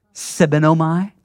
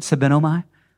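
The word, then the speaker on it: sebenomai (σβέννυμι): It's pronounced sebenomai. That's the Greek word, sebenomai. And that word quench, the word sebenomai, the Greek word, it only ever refers to fire, except in this one case.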